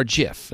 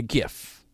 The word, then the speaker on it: GIF: JIF. Now, they talked about this on The Tonight Show. Okay, Jimmy Fallon. I've always called it a JIF, but it's really a GIF, the hard G.